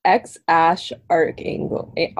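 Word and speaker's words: X Æ A-12: It's X-Ash Archangel, Archangel. Yeah.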